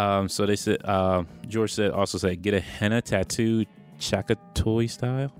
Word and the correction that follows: Chakotay: I'm not sure if I was saying that right. Chakatoy. Chakatoy. – Chakotay.